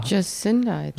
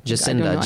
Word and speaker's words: Jacinda: Her name is, how do you pronounce it? Jacinda or Jacinda? – Jacinda. – Jacinda. Jacinda Ardern.